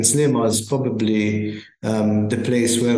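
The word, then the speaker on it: Sliema: I noticed that there's a lot of waterfront construction going on in Salima. Is that correct pronunciation? – Yes.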